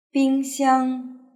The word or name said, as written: bingxiang